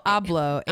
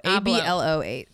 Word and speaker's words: Abloh: How do you say that? Abloh. – Yeah. – I never heard Virgil's name out loud. – Virgil Abloh. Abloh.